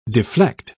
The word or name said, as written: deflect